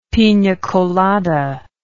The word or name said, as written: pina colada